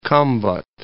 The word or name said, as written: Khambhat